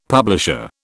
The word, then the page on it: publisher: British English